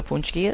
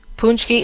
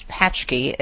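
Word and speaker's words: pączki: Punchki is spelled P-A-C-Z-K-I. It's not pronounced patchki. It's pronounced punchki, even though the way it looks in terms of spelling, it looks like it's patchki, but it's punchki.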